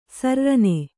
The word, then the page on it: ಸರ್ರನೆ: sarrane